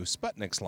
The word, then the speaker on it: Sputnik: But, so what we talk about on this one, though, is that we're referring to Sputnik's launch, mm-hmm. – or Sputnik, which is actually how it's pronounced.